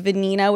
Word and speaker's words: Vanina: So it was Sophia Franco Franklin, and my middle name is Vanina, which rhymes with Sophia.